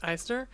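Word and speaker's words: Eichner: Billy Eisner, is that your pronounce that thing? – Yep, Eisner.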